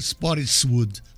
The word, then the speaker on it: Spottiswood: No, no es así. Roger Spottiswood. Así que se pronuncia.